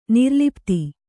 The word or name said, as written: ನಿರ್ಲಪಿತ